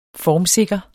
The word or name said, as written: formsikker